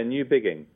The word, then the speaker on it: Newbigging: Newbigging. – Newbigging. Okay, good. – You did a great job. Most people say new beginning, so you did a great job pronouncing it correctly.